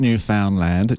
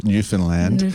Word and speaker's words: Newfoundland: Not Newfoundland, Newfoundland, as I've learned to say it properly,